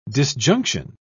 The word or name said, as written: disjunction